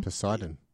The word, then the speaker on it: Poseidon: The other one was, I think I'm saying it right, Poseidon? – Yep. – Is that right? The ship which sinks? – Yes, Poseidon.